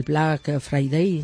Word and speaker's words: Black Friday: Yo como el inglés lo pronuncio fatal, lo del Black Friday, ¿no? – Pues lo has pronunciado perfectamente y nos entendemos todos.